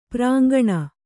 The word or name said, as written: ಪುರಾಂಗನೆ